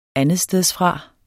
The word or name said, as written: andetstedsfra